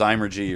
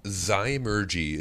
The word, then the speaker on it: Zymurgy: A, they can never pronounce it right because whether it's Zymergy or Zimmergy or any of a million other things, what is the correct pronunciation? Where did that name come from? Where how did you name your brewery? Zymurgy is how you would pronounce it.